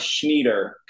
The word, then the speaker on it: Schnieder: Quick German lesson. You always pronounce the second vowel. So mine is IE, which is why it's Schnieder. Kind of like how Diesel is IE, but you don't say Diesel.